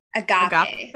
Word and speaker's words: agape: Agape.